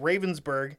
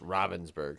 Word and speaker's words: Ravensburg: I think it's pronounced Ravensburg. No, it's Ravensburg. It's it's it's yeah. – Yeah, but I think it's pronounced Ravensburg. – Of course you do, you German fuck.